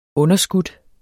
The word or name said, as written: underskudt